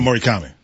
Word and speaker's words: Murakami: Mutataka Murakami. – That was really good. – Thank you. – Say that, not you, oh. – Say that name again. – Mutataka Murakami.